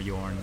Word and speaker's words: Jorn: But that's why I say just Jorn or Jorn.